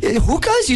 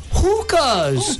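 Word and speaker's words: Hoku's: Get the H-O in it, but yeah, it's hookahs, not hookahs.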